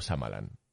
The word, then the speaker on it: Shyamalan: ¿Es Shyamalan o Shyamalan? – Eh, Shyamalan. no, que está aquí en la Wikipedia te pone la pronunciación.